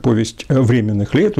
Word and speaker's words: Повесть временных лет: Но вот ту летопись, которую мы знаем как «Повесть временных лет», ударение именно так ставлю,